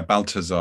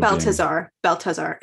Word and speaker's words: Balthasar: Did I say his name correctly? – Yeah, Balthasar, I guess, if you're Balthasar, yeah.